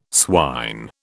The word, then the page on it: swine: British English